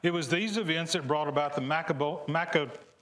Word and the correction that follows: Maccabean: Did I say that right? Maccabean.